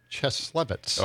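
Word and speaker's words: Cieslewicz: How do you say it? – Cheslevich.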